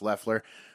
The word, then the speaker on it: Loeffler: Both seats are up for grabs, the regular seat that's currently held by David Perdue and the special seat held by Kelly Loeffler, spelled Loeffler, pronounced Loeffler.